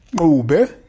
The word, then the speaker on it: Dube: Diese Dube wird Dube ausgesprochen. Das ist von Sprache der Xhosa, also Sprache von Nelson Mandela und ja, in Südafrika. Er kommt oder er kam von diesem Stamm her, deswegen Lucky Dube.